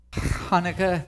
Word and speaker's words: Hanukkah: Is that like a pronunciation thing that I'm getting wrong? – Yes, because it's Hanukkah. When it comes into English, it can be spelled various ways. It's the same word. It just can be spelled differently because it's coming into a different language.